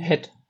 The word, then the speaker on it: Head: Wie spricht man die aus? Head, Head, Head Cycling? Head. Head. Head. Also ja. doch mit Head, okay.